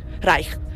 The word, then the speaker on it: Reich: Reich.